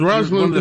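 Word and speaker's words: Rosalynn: Did I say Rosalind or Rosalind? – I said Rosalind. You said it right. – Yes, Rosalind. Rosalind Carter. Say it right.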